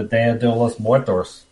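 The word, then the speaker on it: Día de los Muertos: The Dia de los Muertos scene.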